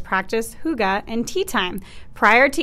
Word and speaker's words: hygge: It's pronounced hygge, but I did not think that was how it was pronounced. H-Y-G-G-E.